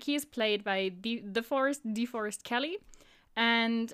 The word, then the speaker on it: DeForest: It's like, I thought DeForest, right? With like an E. – So, like, the forest, like, when you say, oh, wow, there is the forest over there.